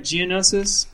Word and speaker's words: Geonosis: let's uh, let's jump back to Geno- Geonosis. Am I saying that right? Geonosis. – That's right. Yeah.